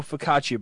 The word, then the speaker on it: focaccia: So you don't know focaccia bread. – Is that how you pronounce it? – Yes.